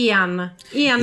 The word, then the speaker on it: Ian: e devo correggerti la pronuncia. Lui Ian Brady e Myra Hindley. – Sì, giusto, Ian, perché è l'unico nome che si legge al contrario in inglese, perché sarebbe esatto. Ian.